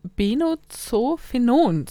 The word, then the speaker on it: Benzophenon: Auf Social Media sind viele der Meinung, dass man Sonnencreme lieber nicht verwenden soll, denn da kann das Molekül Benzophenon, so wird es ausgesprochen, drin sein.